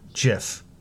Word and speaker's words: GIF: Digital Dreamer asks, how do you pronounce, and he wrote it out, G-I-F, a hard G as in girl, or like the peanut butter, GIF?